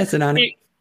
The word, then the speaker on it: Sinani: Sinani, I forget how to pronounce his name, but yeah, Sinani.